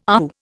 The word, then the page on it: AAU: Indian